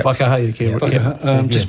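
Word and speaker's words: Whaikaha: Is that how you pronounce it? Faikaha? – Yeah. – Faikaha.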